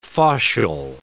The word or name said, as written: faucial